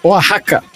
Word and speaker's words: Oaxaca: Não, peraí, mas falou a pronúncia correta aí. Como é que é a pronúncia? Oaxaca.